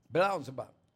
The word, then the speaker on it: Beelzebub: Beelzebub, the ruler of demons.